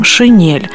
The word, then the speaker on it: шинель: Правильно оно произносится с мягким согласным – «шинель».